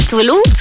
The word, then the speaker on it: Tove Lo: Tuvilu, básicamente Tuvilu, así es como se pronuncia en sueco, aunque se escribe Tove Lo,